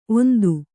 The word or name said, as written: ಓಂತಿ